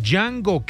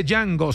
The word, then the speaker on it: Django: Hace días tuve una discusión con un señor que me decía que yo estaba equivocado, que no se dice Django, se dice Django, decía el señor, pero la pronunciación hace parte del idioma romaní que elimina la D y le pone todo el énfasis en la Y, de donde viene el origen del nombre de Django, que se pronunciaría exactamente así.